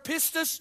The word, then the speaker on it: pistis: The word faith is the word, Greek word pistis. I think I said that right. It's P-I-S-T-I-S.